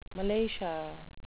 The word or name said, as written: malacia